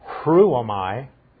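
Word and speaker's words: Hruomai (ῥύομαι): The Greek word behind rescued is hruomai. And I always have trouble. It's an R with a rough breathing, so it'd be pronounced like H-R. Hruomai.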